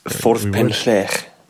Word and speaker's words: Ffordd Penllech: It is Ffordd Penllech.